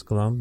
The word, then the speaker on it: Gloam: I one of the things I marvel at in when listening to the new record, Gloam, I hope, am I pronouncing that correctly? I hope I am. Mm-hmm. It's Gloam. Yeah. – You got it.